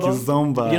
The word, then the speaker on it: Kizomba: But I think it's Kizomba. – Kizomba.